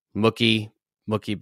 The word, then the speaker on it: Mookie: I would like to just take a moment to pay homage to the fact that you say Mr. Betts's first name as if it rhymes with Wookiee, like from Star Wars. Mookie Betts.